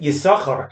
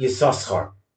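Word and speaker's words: Yisachar (יששכר): We pronounce it Yisachar and not Yisoschar,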